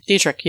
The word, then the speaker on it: Dietrich: There was Marlene Dietrich. Dietrich? I don't know how you actually say her name in English. Dietrich? Dietrich, yeah.